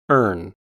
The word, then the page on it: erne: American English